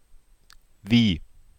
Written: wie

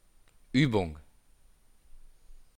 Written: Uebung

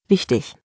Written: wichtig

